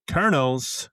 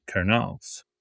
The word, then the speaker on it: Kernels: What about the Cedar Rapid Kernels, spelled like corn kernel? Cedar Rapid Kernels.